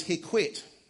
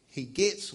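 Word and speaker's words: Heqet: That's a picture of the goddess Hekwet, or Heget, or there's a number of different ways you could pronounce her name.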